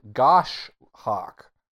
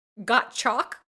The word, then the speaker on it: goshawk: I thought you had said gosh hawk. – I probably did because it's spelled G-O-S-H-A-W-K. So yes. – There's an S-H in the middle there. – But separate syllables.